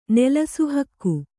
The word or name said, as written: ನೆಲಸುಹಕ್ಕು